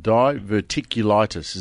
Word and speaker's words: diverticulitis: We're going to talk about diverticulitis.